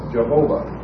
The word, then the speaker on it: Jehovah: And also, when you put something into English, in English pronunciation, or anglicize it, as it's termed, this Y sound became a J, and the W sound became a V, hard V, and you end up with Jehovah.